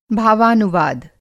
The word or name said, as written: भावानुवाद